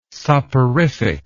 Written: soporific

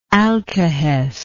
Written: alkahestical

